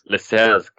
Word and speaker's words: Lascelles: But apart from Trent and Mo and Lascelles, the name that I cannot, I completely butchered that pronunciation. – Lascelles. – God, I knew. Lascelles. That's just the Irish way of saying it.